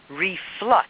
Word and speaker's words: reflux: Okay, so R-E-F-L-E-X, reflex. – Right. – It's something that happens when you hit your knee. It's a reflexive action. Reflux. – Right, reflux. I'm saying it wrong. Absolutely. Right, reflux.